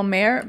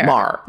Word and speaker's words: Maher: Bill Mayer? – Murray? – The guy who. – Bill Mayer? – Mar.